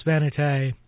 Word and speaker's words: Vanité: Here's Rudy Weedoff's lovely piece, Valse Vanity, or Vanité. Let's say it properly.